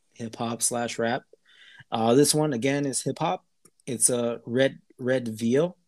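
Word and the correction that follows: Redveil: Am I saying that right, Thomas? – Red. – Or Eric, am I saying that right? Red Veal. Red Veil. – Like a veil.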